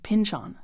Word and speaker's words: Pynchon: By the way, it turns out he pronounces it Pynchon, so I will too.